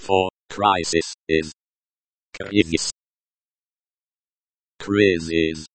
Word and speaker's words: кризис: The pronunciation is krizis. The I in krizis sounds similar to the E in C. The S at the end sounds like the S in kiss, not the S in bus.